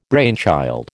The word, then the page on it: brainchild: American English